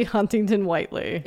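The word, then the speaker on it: Huntington-Whiteley: Rosie Huntington Whiteley.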